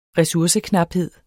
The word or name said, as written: resurseknaphed